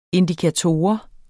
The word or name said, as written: indikator